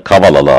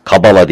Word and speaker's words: Kavala: Kabala değil, kavala. Gerçi Yunan alfabesinde B, beta, V okunuyor. Yani kabala yazılan kavala diye okunmaktadır.